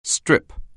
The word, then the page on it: strip: anglais